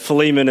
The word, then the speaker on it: Philemon: And Luke says some people say Philemon, but Philemon is how you pronounce that.